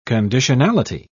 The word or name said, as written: conditionality